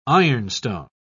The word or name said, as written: ironstone